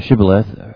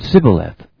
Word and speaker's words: Shibboleth: Gileadites pronounce the word Shibboleth. Oh yeah, the Gileadites produced the word Shibboleth, but Ephraimites said Sibboleth. Anyone who didn't pronounce the initial SH was killed on the spot.